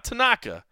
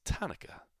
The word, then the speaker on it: Tanaka: You'll notice the change in pronunciation that John Anik was so deftly using this week. It's not Tanaka. It's Tanaka. Which kind of made me think of the Hanukkah song.